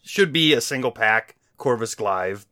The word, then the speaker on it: Glaive: but we're going to get Loki and Corvus Glaive. Glaive, yes, I said it wrong.